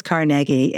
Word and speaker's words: Carnegie: It is Carnegie, and you're correct about that. So, you know, he came from Dunfermline, Scotland, and that was the pronunciation.